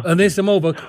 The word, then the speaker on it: Anisimova: Anisimova.